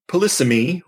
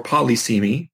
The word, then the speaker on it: polysemy: But polysemy or polysemy, both pronunciations are acceptable, exists in all natural languages, so it gives you something to think about.